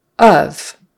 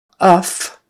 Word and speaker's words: of: Of is a short U sound. It's spelled with an O, but it's a short U sound. And that F, say it like a V. It's not uff or off or off. It's of. So voiced F becomes a V, right?